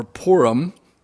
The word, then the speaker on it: Purim: It's called the Feast of Lots, or Purim, is how it's pronounced.